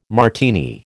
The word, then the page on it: Martini: American English